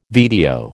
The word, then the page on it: VTO: American English